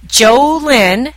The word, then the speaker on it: Jolynn: The Jolynn, J-O capital L-Y-N-N, Braley, capital B-R-A-L-E-Y, show.